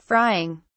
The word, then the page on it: frying: British English